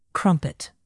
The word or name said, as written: crumpet